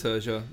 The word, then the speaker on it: Liturgia: They brought out an album called Liturgia. Again, I could be saying it wrong. – But Liturgia. That sounds right.